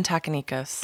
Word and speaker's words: Takanikos: Jasmine Takanikos.